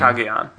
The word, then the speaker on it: Cagayan: I said it right this time. – Cagayan, yes.